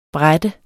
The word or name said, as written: bræt